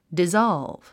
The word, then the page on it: dissolve: dizάlv; ディザルブ